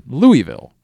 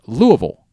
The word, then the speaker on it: Louisville: And for most of my life I said Louisville, right? – Yeah. – It's very much not Louisville. – Is Louisville? – No, it's Louisville. – Louisville. – Louisville. That's how they say it there.